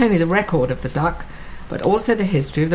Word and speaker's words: DUKW: The DUKW, pronounced duck, was a sight to see, an enormous vehicle embedded in the history of our country.